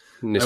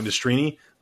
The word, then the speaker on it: Nastrini: It's like you can kind of let them you can kind of let them like what was it Nistrani? You said his name was. Nastrini. – I always pronounce names wrong. Nastrini.